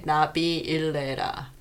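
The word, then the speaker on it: 나빌레라: Navillera. – Navillera. – Okay. So it means not... If you were to say it like the actual, like, grammatically correct way, Navillera.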